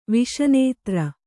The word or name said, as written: ವಿಷನೇತ್ರ